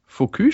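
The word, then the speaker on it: Focus: Focu spricht man das aus? – Ja, Focu um Interaktive oder so ähnlich.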